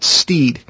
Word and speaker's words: Stead: It's spelled S-T-E-A-D, but I believe he pronounced it Steed.